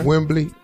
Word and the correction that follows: Wemby: Just call him Wemby, not Wembley.